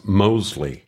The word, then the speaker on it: Mosley: That's Mosley. – Oh, yes, with a silent T. – No, like I told you last time, there is no T, silent or otherwise. It's just Mosley.